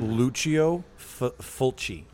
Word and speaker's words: Lucio Fulci: No, I know this, but I've been mispronouncing it all night. – It's hard to pronounce because it's a foreign name, right? – Yes. – Refresh my memory. Is the movie, is it 78 or 81? – 79. – And it's Lucio Fulci.